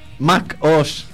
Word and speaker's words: Mac OS: Como decíamos anteriormente, Apple ha presentado oficialmente la próxima versión del Mac OS X. Se dice así, nunca lo supe. – Sí, es Mac OS.